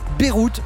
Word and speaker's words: Beirut: Au menu ce soir, un groupe qui sonne comme une destination, Beyrouth, ça se prononce comme la ville libanaise, mais ça s'écrit B-E-I-R-U-T.